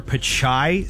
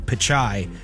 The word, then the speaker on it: Pichai: The most he did as far as talking AI was make a joke that the last two letters of his name, Pichai, are AI. Sound like AI.